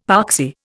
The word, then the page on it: poxy: Indian